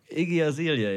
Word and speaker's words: Iggy Azalea: Iggy Azalea, ja genau.